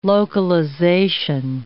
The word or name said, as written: localization